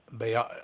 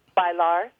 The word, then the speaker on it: bailar: Sheila E., the undisputed queen of percussion, announced a single release of Bemba Colora from her upcoming salsa album, Bayar. How do you say it? Bayar? Is that how you say it? – Bailar. – Bailar. So you say the L. Yeah. That's a hard L.